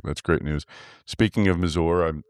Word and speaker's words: Mazor: No, it's a great next generation for, I think it was Mazor is how that was said. And, sorry. – No, it's spelled like Razor. – Mazor.